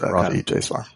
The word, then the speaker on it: Ravi Jaiswar: It's pronounced Ravi Jaiswar.